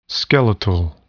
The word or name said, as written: skeletal